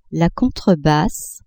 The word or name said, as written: contrebasse